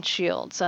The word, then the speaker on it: Shields: On Twitter, I am at Allison Shields, and that's Allison with two L's. – And your last name is spelled S-H-I-E-L-D-S, like yes.